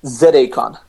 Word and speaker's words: Zacon: How do you pronounce it? Is it Zacon, Zacon? – Zacon. – Zacon. Sorry. Okay. Yeah. So, Zacon. Oh, like an acorn.